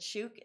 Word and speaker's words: Shoeq: So Shoeq, which is S H O E Q, but they pronounce it Shouk, is a Dubai based homegrown business started by an Aussie expat, Kate Taverner.